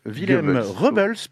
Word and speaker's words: Willem Rubbels: Et du coup, c'est Willem Rubbels, puisque c'est comme ça que ça se prononce. Il l'a dit lui-même.